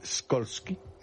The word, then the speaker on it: Skolsky: ¿Cómo se pronunciaría en polaco Skolsky? – Skolsky. Como tú lo has dicho.